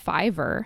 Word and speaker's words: Fiverr: So the first resource that I love is Fiverr, and that's spelled F-I-V-E-R-R.